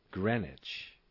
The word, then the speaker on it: Greenwich: I.E., that is to say, GMT, Greenwich Mean Time, and watch the pronunciation here, Greenwich.